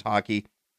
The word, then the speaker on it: Haki: And in this form, he's got what's called Conqueror's Haki. It's not like hockey, like Canadian hockey. That's insane. This is, I guess, hockey is like the right way to pronounce it.